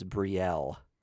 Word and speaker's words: Brielle: So that was the thing that popped up in this free comic book day comic was B-R-I-E-L-L-E. I think you pronounce it Brielle.